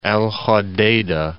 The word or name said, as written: Al Hudaydah